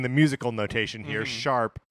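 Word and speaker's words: ♯: Yeah, which they have to do because the name of the game again is Tokyo Mirage Sessions Sharp FE, and I'm saying sharp because I know that that's how it's pronounced, but it's like a hashtag.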